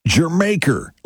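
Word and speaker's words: D'yer Mak'er: The way you pronounce that song is Jermaker, like Jamaica, but Jermaker. Yeah, it's, I know it's spelled Dyer Maker, but that's not how you say it.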